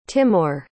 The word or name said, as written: Timour